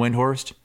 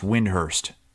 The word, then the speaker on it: Windhorst: Windhorst. – He said it's actually pronounced Windhurst.